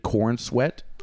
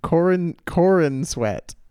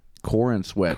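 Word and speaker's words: Corenswet: David Corn Sweat? – No, I don't think it's Corn. Let's see if I pronounce it. Corin Sweat. – Corin Sweat.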